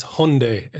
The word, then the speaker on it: Hyundai: And the amount of people that will comment to say it's Hyundai, it rhymes with Sunday.